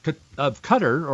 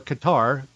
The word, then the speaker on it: Qatar: In fact, the Emir of Qatar, or Qatar, Q-A-T-A-R, it's pronounced both ways.